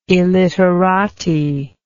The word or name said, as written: illiterati